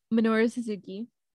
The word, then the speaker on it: Minoru Suzuki: Minoru Suzuki.